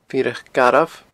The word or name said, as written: Fireach Garbh